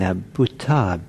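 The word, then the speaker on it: bhūta: And the Pali expression, yata bhuta, Y-A-T-H, just it means like as. And bhuta, B-H, long U-T-A.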